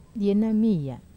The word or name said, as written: ena mia